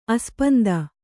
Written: ಆಸ್ಪಂದ

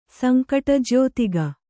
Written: ಸಂಕಟಜ್ಯೋತಿಗ

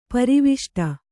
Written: ಪ್ರವೇಷ್ಟ

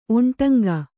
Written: ಒಂಟಂಗ

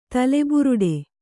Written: ತಲೆಬುರುಡೆ

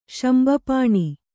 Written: ಶಂಬಪಾಣಿ

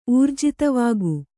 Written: ಊರ್ಜಿತವಾಗು

